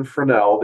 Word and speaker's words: Fresnel: In the early 1800s, a Frenchman named Augustin Fresnel, the S is silent because he's French.